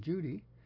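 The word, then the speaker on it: Cudi: Mount Kudi, though pronounced in Turkish as Judy, in the Kurdish mountains.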